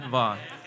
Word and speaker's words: Vuong: My name is Vuong. It's like King Kong, but with a V. All right, Vuong. So remember that. The U is silent.